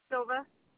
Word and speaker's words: Silva: Silva. – Okay, can you spell that for me? – S-I-L-V-A.